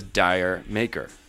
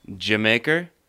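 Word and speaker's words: D'yer Mak'er: Do you know how you actually pronounce it? – How? – Jamaker. It's from an old joke. – What? – It's from an old joke. So they're doing a Jamaican sound there.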